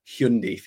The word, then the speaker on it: Hyundai: There may be a Hyundai for you, which is the correct pronunciation as they have informed us.